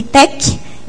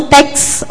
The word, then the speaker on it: TeX: Então, por que a gente pronuncia LaTeX e TEC e não TEX ou LaTeX? Porque o TEC, da linguagem original, quer dizer arte em grego.